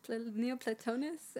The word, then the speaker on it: Neoplatonist: Neoplatonist.